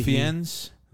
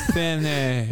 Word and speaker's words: Fiennes: Yeah, how do you say his last name? F-I-E. – Fiennes? – Yeah. – Fien? – I'll let you do it. – Fiennes.